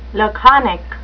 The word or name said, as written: laconic